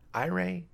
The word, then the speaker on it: Eury: Am I saying that right? Iray, according to Baseball Reference.